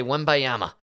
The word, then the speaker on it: Wembanyama: I go with my instinct and I say Wembanyama.